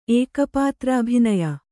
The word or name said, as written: ಏಕಪಾತ್ರಾಭಿನಯ